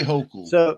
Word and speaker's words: Hochul: How do you say the last name? Hochul? Y'all's governor? – We say Hochul.